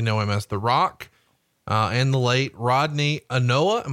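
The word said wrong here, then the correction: Anoa'i: Am I saying that right? – Anawai. – Anawai. That's Yokozuna. Anawai, yes.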